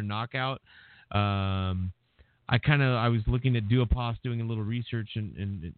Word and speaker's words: Duhaupas: But Gerald Big Baby Miller and Johan Duopas, am I saying that right? Or is it Duhapas? – Yeah. Duopas. That's how I've always heard that.